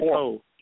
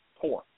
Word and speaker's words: poor: It's pronounced poor. You got to have the R on the end of poor or else you know it's just you know pole, and that I don't even know what they mean.